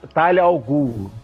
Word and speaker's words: Talia Al Ghul: Ih, como é que fala? – Talia Al Ghul.